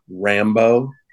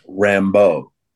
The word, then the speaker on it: Rambeau: So it's not Rambeau. It's Rambeau. The emphasis is on the second syllable.